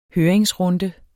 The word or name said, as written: høringsrunde